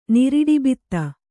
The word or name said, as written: ನಿರಿಡಿಬಿತ್ತ